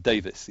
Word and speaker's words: Davies: I think that the Welsh people who produced these names in the first place pronounce that Davis, even if it's got an E in it.